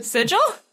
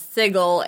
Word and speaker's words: sigil: Like, quick aside, there's a period in Critical Role where Matt Mercer was pronouncing Sigil as Sigil, and people made fun of him because he had only ever read it and was never... He never learned it from being told.